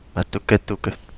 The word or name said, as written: Matuketuke